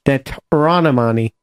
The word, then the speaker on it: Deuteronomy: Not Determinari. Deuteronomy. – No, you said it wrong.